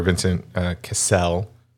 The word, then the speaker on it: Vincent Cassel: There's that little exchange with the captain's character, Vincent Cassell. Did I say that right? – Yes.